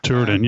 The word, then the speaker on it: Turin: He was flying a Texan, which is very similar to the Sportster from Turin, if I pronounce it correctly. – Turin, yeah, just down the road from my place, actually.